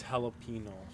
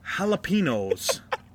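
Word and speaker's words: jalapeño: Big Spanish guy, jalapenos, okay? Jalapenos. – Well, go ahead and state, you know, how it's supposed to be said. – Jalapeno. – Jalapeno. – Or jalapeno. Yeah, and it's not jalapeno.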